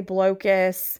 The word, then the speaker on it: Blokus: Another one that plays kind of similar is going to be Blokus. Some people say Blokus, however you want to pronounce it.